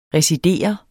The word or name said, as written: residere